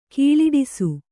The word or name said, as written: ಘೋಳಿಡಿಸು